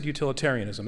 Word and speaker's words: utilitarianism: Did I add an extra syllable? I said utilitarianism, that's correct. – That's right.